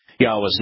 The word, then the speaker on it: Yahweh: Yahweh's name is pronounced using three of these vowels, the Yod, the He, and the Waw. Vocalized Yahweh, collectively,